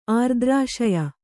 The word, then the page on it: ಆರ್ದ್ರಾಶಯ: Kannada